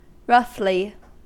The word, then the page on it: roughly: American English